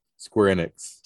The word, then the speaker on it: Square Enix: Square Enix.